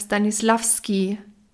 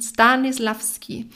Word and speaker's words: Stanislavski: Denn die Stanislav-Methode, nein, nicht Stanislav, Stanislavski, wieder was falsch ausgesprochen, Stanislavski. Das